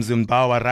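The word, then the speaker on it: Zinnbauer: I like calling your head coach Zimbabwe because his name Zimbawa rhymes with Zimbabwe.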